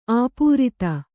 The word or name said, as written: ಅಪ್ರತಿ